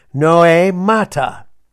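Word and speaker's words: noēmata: And that is where we get no, noe, N-O-E, long E, mata, M-A-T-A, noe mata.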